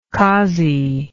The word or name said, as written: cossie